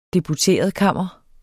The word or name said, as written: deputeretkammer